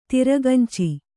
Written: ತಿರಗಂಚಿ

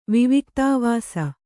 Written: ವಿವಿಕ್ತಾವಾಸ

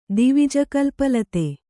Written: ದಿವಿಜಕಲ್ಪಲತೆ

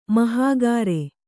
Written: ಮಹಾಗಾರೆ

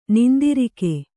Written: ನಿಂದಿರಿಕೆ

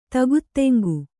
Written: ಧಕ್ಕಾಧಿಂಗಿ

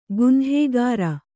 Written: ಗುನ್ಹೇಗಾರ

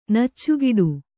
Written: ನೆಚ್ಚುಗೆಡು

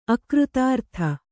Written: ಅಕೃತಾರ್ಥ

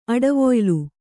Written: ಅಡವೊಯ್ಲು

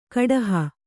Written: ಕುಡುಹಿ

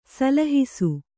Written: ಸಲಹಿಸು

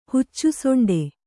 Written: ಹುಚ್ಚುಸೊಂಡೆ

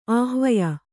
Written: ಆಹ್ವಯ

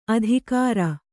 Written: ಆದಿಗಿರಿ

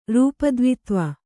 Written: ರೂಪದ್ವಿತ್ವ

